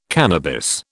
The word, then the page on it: cannabis: British English